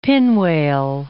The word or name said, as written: pinwale